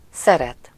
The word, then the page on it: aimer: /e.me/; /ɛ.me/